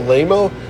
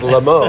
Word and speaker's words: Lamo: No, it was Lamo the clown, and people would go, "Oh, Lamo?" No, it's pronounced Lamo. It's French.